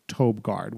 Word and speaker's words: Tobe: It looks like Toby, but it's pronounced Tobe Gard, which I think is interesting.